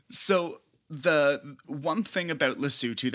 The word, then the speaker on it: Lesotho: The country we'll be learning about this week is Lesotho, is how it is pronounced.